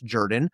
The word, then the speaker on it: Jordan: And yeah, there was a guy named Hamilton Jordan, spelled Jordan, but I learned doing my audiobook, it's pronounced Jordan, who was the chief of staff in the White House, was accused of sniffing cocaine, snorting cocaine at Studio 54.